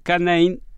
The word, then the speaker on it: canine: Él es integrante del grupo Topos en el equipo K9. Canine es eh, como se pronuncia en inglés y que tiene que ver con eh,